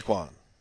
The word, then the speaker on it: Roquan: How else would one pronounce Roquan Smith? – Raekwon.